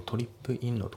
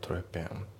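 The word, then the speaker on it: trippin': であと発音ですけど、トリップインのところがリンキングでトレペンだね。トリップインじゃなくてトレペンですね。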